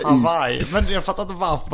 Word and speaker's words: Hawaii: Det är ett j som vi har lagt till liksom. – Hawaii. – Ja, det är så det ska uttalas. Hawaii.